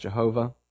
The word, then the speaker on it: Yahweh: So usually when we have Lord in all caps, that is the name Yahweh, the name of God, sometimes pronounced Jehovah.